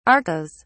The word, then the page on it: arkose: British English